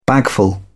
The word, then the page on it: bagful: British English